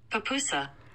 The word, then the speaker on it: pupusa: Residents of a town in El Salvador broke a Guinness World Record when they baked a pupusa measuring 18 feet across.